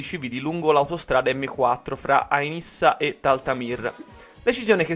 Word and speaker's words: Ain Issa: L'area al centro dei combattimenti rimane quella attorno ad Ain Issa, leggera Ain Issa, si legge Ain Issa, dove numerosi tentativi di avanzata sono stati respinti dalle forze democratiche siriane.